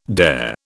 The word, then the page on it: dare: British English